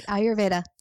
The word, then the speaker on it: Ayurveda: Ayurveda.